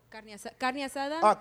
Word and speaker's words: carne asada: Carne asada. – Sorry. Yeah, I know that. – I'm sorry. – I've seen that on the menu. – Let me pronounce it correctly. No. Carne asada.